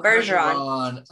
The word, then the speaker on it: Bergeron: Bergeron.